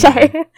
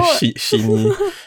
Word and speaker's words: Shein: Is it Shine or Shein? – Shine. No. – Shein. Shein. – No lah. I think it's called Shein.